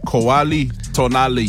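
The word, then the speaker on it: Cualli tonalli: How do you say that? – Kuali Tonali. – Kuali Tonali. – That is ancient Aztec. – Kuala Tunali.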